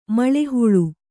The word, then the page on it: ಮುಳ್ಳುಹುಳ: muḷḷu huḷa